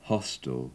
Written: hostel

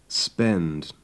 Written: spend

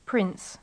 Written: prince

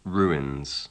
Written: ruins

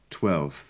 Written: twelfth

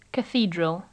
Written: cathedral

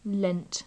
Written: lent

